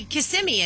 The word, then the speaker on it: Kissimmee: She's the one who's confused me, and I don't know how to say Kissimmee anymore. Yeah, yeah. I think, did I just say it right? – You said it right.